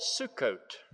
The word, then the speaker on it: Sukkot: They called it Sukkot, spelled S-U-K-K-O-T-H, but pronounced like a woman's name, Sue, and a woman's garment, a coat, Sukkot.